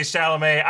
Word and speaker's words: Chalamet: Yeah, you sound like Timothy Chandelier and a half. Yeah. I don't know how to pronounce his last name. – Quick aside on Timothy Chalamet.